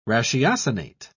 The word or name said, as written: ratiocinate